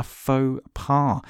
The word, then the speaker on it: faux pas: Okay, so he made a faux pas. Really posh vocabulary that. Although it's only two syllables, I still didn't actually know that because it's kind of French.